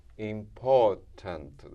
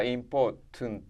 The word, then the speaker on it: Important: 長い単語ですけど、 Important ですね。アクセントここにあります。やってみましょう。で、こっちは Important、ここがない Tunt で、ですので子音が 3つも続きます。 Tunt ですね。